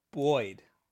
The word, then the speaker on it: buoyed: Yeah, it's B-U-O-Y-E-D. – B-U-O-Y-E-D. B-U-O-Y-E-D. Boyd. – Right? Like buoy. Like a buoy.